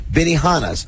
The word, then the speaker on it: Benihana: First one to call and get this right, Frank, we'll take care of you at Riverfront Steakhouse or Benihana's.